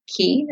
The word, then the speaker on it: Kee: And we have the bronzer in Key, which is K-E-E. It's pronounced Key as in keychain.